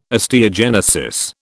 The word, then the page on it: osteogenesis: British English